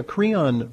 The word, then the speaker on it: Creon: Okay, so yeah, Creon, for a listening audience, I mean, rhymes with Freon, but it's not antifreeze.